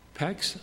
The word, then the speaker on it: Pecs: Also, Phoebe Pex, I guess is how you say the name, P-E-C-S, faithfully worships with us via the internet, has asked the church to pray for her 77-year-old cousin, Sally McCauley Freedy, in Pennsylvania.